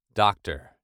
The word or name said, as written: doctor